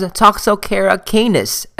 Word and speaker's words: Toxocara canis: Our next trematode transmitted by eggs is Toxocara canis. The British would pronounce this as Toxocara canis, as well as Toxocara cati.